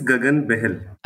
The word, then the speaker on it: Gagan Behl: My name is Gagan Behl.